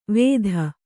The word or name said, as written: ವೇದ